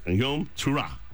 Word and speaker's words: Yom Teruah: You shall do no customary work, for it is a day of blowing the trumpets, or a Yom Teruah, all right? Yom Teruah. That's a probably better pronunciation there.